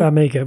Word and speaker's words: Farmiga: Farmiga. Well, you helped me out with Black Stance and whatever. So Krenensky. – What is it with these names? Yes. Vera Farmiga, who was your choice this week.